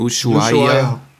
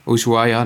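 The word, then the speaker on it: Ushuaïa: Und zwar bleiben High Ibiza und den Namen konnte ich noch nie aussprechen, Ushuaia. – Ushuaia, ne? Ja.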